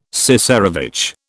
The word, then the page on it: cesarevitch: British English